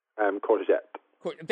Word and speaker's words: courgette: How do you pronounce it? – Courgette. – That sounds like a car.